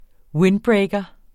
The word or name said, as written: windbreaker